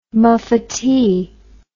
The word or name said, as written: muffetee